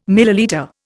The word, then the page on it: millilitre: Indian